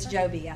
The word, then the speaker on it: Jobe: Carrie Joby is Joby, I think.